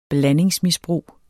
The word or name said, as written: blandingsmisbrug